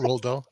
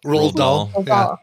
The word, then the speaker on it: Roald Dahl: Roald Dahl. – Roald Dahl.